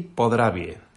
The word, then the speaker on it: Podravje: Eslovenia tiene tres grandes regiones vitivinícolas que voy a intentar pronunciar, que se llaman Primorska, Posavje y Podravje, uh-huh. ¿Vale? La J es con una I.